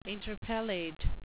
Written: interpellate

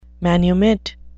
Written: manumit

